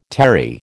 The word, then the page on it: terry: American English